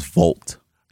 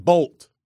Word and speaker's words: Volt: The correct pronunciation of this color or name is Volt. – Watch this. – Volt Green. – Who cares? So, Volt Green? Volt? Not like Bolt, but Volt. – V-O-L-T, Volt.